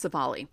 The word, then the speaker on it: Civale: Is that how you say his name? Civale or Civale? I'm not sure, but he's 0-1 with a 6. Oh, that I think that's it. It's Civale. 0-1 with a 6.14 ERA.